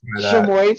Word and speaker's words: chamoy: Did you call it chamois spelled like chamois, like C H A M O I S? – Uh, C H A M O Y.